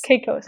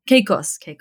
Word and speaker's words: Caicos: I'm going to say this wrong, Caicos Islands. – Caicos. – For which you were a co-author. Caicos, perfect. Turks and Caicos Islands, for which you were a co-author.